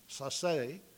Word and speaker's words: Sasse: And Professors Sasse, actually, is how you would pronounce his name, Sasse,